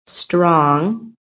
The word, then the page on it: STRONG: American English